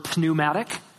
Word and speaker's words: pneumatic: Okay, we get our English word pneumatic, right, where the P is silent.